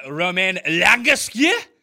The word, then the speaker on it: Romain Langasque: Yeah, the guy at 6,600 that I like is Romain Langasque, and I butchered his, his name. He's uh, French, so I might leave that to you to pronounce his name. – Oh, He's oh, a guy that. Oh, you mean my main man, Romain Langasque? There we go. – That's how you say it.